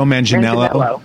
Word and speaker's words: Manganiello: It's like Idris Elba, Joe Manganiello. How do you say his name? – Joe Manganiello.